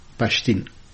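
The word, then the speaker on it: Pashteen: از شبه که در برخی مناطق خیبر پشتونخوا و همچنان در برخی بخشای افغانستان مثل خوست حرف واو به شکل یا معمولا تلفظ میشه. به همین دلیل مسعود که بعضی از رهبران تحریک طالبان پاکستان تخلص میکنن مسید تلفظ میشه و به همین ترتیب پشتون پشتین تلفظ میشه.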